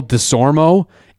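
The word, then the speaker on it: Desormeaux: E-A-U-X? – Yeah. – That's how his name is spelled. Michael DeSormo, M-E-A-U-X at the end.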